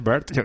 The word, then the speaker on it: Wert: Bert, ¿no? Se pronuncia Bert.